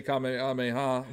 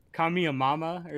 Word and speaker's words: Kamehameha: I think the funniest pronunciation was, like, Kamiyamama or something like that. that one was like, come on, man, you're not even trying to pronounce it correctly.